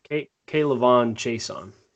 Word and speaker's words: K'Lavon Chaisson: chess piece like K'Lavon Chaisson? Is that how you say it? Edge. – K'Lavon Chaisson.